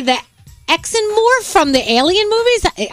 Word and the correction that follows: Xenomorph: How do you say that? – Okay. – Xenomorph.